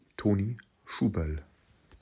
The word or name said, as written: Toni Schuberl